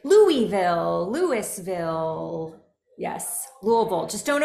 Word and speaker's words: Louisville: And we, the two of us, know how to pronounce it, Louisville.